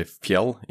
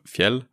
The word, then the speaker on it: Fjell: So usually Americans and they say Fjell instead of Fjell. Because the L's are different in English, but yeah, Klippe is Klippe.